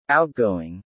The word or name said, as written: outgoing